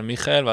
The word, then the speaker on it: מיכאל: שכתוב לו מיכאל על הזרוע, אה, וזה מצחיק, הוא כזה אומר מיכאל, ואז אני אומר לו כן מיכאל, ואז הוא שואל אותי כזה, is that how you say it?